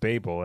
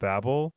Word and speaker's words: Babel: Apparently, I think it's pronounced Babel and not Babel, which I think is like an important distinction, because I looked it up, and apparently Josiah Bancroft had no intention of it.